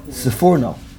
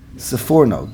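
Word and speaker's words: Sforno: How do you say it? Sipurno? Sifurno. With a fe, not a pe. Sifurno.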